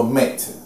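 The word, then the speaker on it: Maat: 正义那个字神格化这样，蒙着眼睛这样。啊，埃及里面那个，埃及里面那个，它是念作 Mat，就马特。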